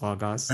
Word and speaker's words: Logos: I was like, well, it's Greek. It's actually pronounced Logos.